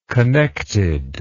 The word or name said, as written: connected